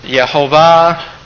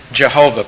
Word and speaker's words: Jehovah: And so by putting some vowels together with those consonants, we come up with Yahweh or Yehovah, or the way that we anglicize it, we say Jehovah, pronouncing the J with that J sound.